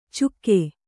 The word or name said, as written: ಚುಕ್ಕೆ